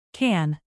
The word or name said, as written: can